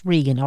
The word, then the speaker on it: Regan: By Lisa I think it's pronounced Regan, R-E-G-A-N.